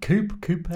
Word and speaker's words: coupe: I've never known how you pronounce this. Not champagne, not a flute. The coupe. – Yeah.